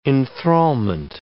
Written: enthrallment

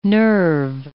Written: nerve

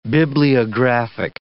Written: bibliographic

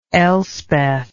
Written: elspeth

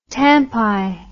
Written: tampi

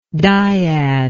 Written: diad

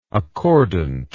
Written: accordantly

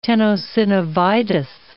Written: tenosynovitis

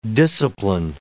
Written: discipline

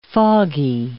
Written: foggy

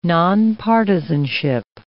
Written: nonpartisanship

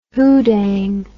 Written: hoodang

